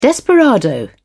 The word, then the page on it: desperado: British English